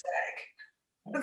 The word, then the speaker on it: Svag: And it's, I think it's called pronounced Svai, but it's Svag. It sounds better in Swedish.